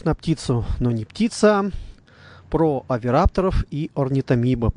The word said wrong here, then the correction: орнитомимидов: орнитомимидов, если я правильно произношу. Поправьте меня, Ярослав Александрович. – Да, все правильно.